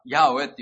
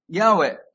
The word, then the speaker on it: ヤーウェ: まあ、ヤーウェっていうふうに、おそらく発音するだろうというふうに言われてはいますけど。でもこの主、ヤーウェ、もしくは、その神の名前というものは、それはあるという方なんですよね。